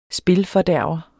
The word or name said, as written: spilfordærver